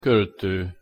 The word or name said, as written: kolto